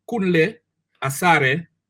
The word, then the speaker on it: Kunle Asare: So if you say it like how it's supposed to pronounce, it's supposed to be Kunle Asare McCarthy. Because I know my African last name.